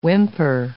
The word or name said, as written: whimper